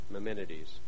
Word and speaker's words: Maimonides: If you'd like to spell his name, it's Maimon, the last two syllables are IDES, Maimonides.